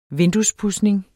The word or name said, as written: vinduespudsning